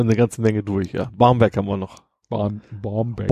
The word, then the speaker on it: Barmbek: Ein Dehnungs-C ist, normalerweise sagt man ja CK, dann wird der Beck. Vokal davor kurz ausgesprochen, ja. also Beck. – Ja. Aber ein C kann auch ein Dehnungs-C sein und wird eben Barmbek ausgesprochen.